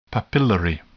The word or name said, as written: papillary